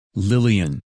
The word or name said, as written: Lillian